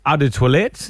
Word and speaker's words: Eau de Toilette: Eau de Toilette, that one. – Yeah, that one. – That is the correct pronunciation too.